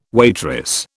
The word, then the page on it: waitress: British English